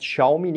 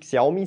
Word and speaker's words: Xiaomi: Also zum Beispiel, ich habe letztens erst für das Casino-Portfolio Xiaomi, habt ihr mir gesagt, das heißt Xiaomi, nicht Xiaomi, sondern Xiaomi, hoffentlich habe ich es richtig ausgesprochen, 1.000 Aktien davon gekauft.